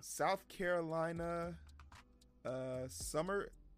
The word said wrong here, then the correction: Sumter: The town's name is Summer. Summer is spelt Summer, but the T for an M. S-M-T-E-R. – It's Sumter. – Sumter? Okay, there you go. – Yeah. Sumter, South Carolina.